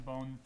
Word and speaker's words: Bone: Hi, I'm Brian Bone.